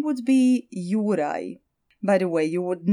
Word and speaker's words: Juraj: It's J-U-R-A-J, Juraj, which is kind of cool because it almost rhymes with his friend, the dragon, in Croatian, Zmaj.